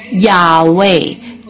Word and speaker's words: 雅威: 我们在这里必须注意到主的名字哦，耶和华，也就是许多人将耶和华发音为雅威，就是希伯来文中神的名的字面的发音。